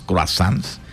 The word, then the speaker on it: croissant: Son unos croissants. Bueno, ahí dicen que se pronuncia croissant. Croissant.